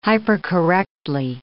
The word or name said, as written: hypercorrectly